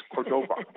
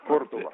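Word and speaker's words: Cordova: Correcto, pero en el caso de Marty Córdoba, que fue novato del año, él se reunía con la prensa y le decía, por favor, no pronuncien así mi nombre. No es Córdoba, es Córdoba.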